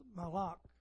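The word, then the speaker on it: malak (מלאך): If you look at the Hebrew for angel, the word is pronounced malak, malak, and it means messenger.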